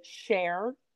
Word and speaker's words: SHARE: Number 8 is actually, it's SHARR. I just want to spell that out for our listeners, but it's pronounced SHARE.